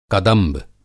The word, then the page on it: कदंब: Hindi